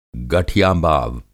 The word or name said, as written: गठियाबाव